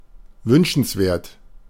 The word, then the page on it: desirable: /dɪ.ˈzaɪ.əɹ.ə.bəl/